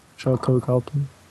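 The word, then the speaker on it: Sharlto Copley: Yeah, now, I'm never sure how to pronounce his first name. Charlton Copley? – Charlton Copley. Sounds good to me.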